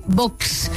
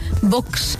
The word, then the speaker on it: Vox: Parece, oye, pero es de los pocos que pronuncia muy bien Vox. – Vox. – Claro, será porque es un jefe de prensa y sabe que hay que pronunciarlo con cierto empaque, ¿no? O sea, Vox.